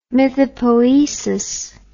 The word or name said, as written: mythopoesis